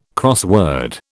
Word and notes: crossword: British English

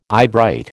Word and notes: eyebright: American English

